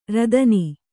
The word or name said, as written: ರೋದನಿ